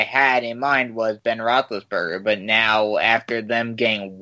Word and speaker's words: Roethlisberger: Burger Rothless Roethlisberger.